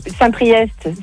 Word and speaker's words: Saint-Priest: T'as Saint-Priest, c'est EST, tu le prononces.